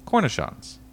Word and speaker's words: cornichons: Cornichons.